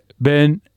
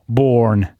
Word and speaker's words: Melbourne: Melbourne, perché Ben e non Born? – È una riduzione fonetica, è un fenomeno molto tipico degli inglesi. Piace la prima sillaba e quell'ultima no. E cercano sempre di ridurre la pronuncia. – Perché teoricamente dovrebbe essere Born.